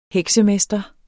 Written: heksemester

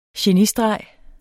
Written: genistreg

